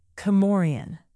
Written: comorian

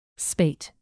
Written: spate

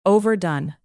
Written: overdone

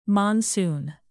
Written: monsoon

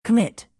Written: commit

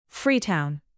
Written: freetown

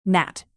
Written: gnat

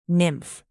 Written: nymph

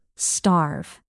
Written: starve

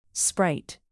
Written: sprite

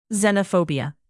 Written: xenophobia

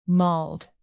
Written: mauled

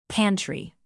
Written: pantry

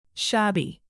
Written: shabby

